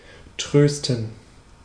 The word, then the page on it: trösten: /ˈtʁøːstən/; [ˈtʰʁøːstn̩]; /'trøːstən/